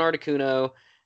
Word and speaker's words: Articuno: Articuno. – The blue. Yeah. Articuno.